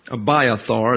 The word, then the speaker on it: Abiathar: But Abiathar is the correct pronunciation.